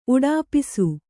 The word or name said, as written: ಉಡಾಪಿಸು